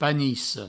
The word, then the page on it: panica: panisso